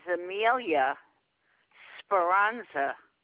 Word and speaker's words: Amelia Speranza: No, my name is Amelia Speranza. Do you need me to spell that? – Yes, ma'am. – Amelia, A-M-E-L-I.